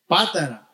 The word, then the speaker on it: Patara: help me with this word, Patara, is that correct? Yes? Patara, because there's an accent on the A, but sometimes I forget how to pronounce it.